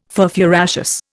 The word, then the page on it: furfuraceous: Indian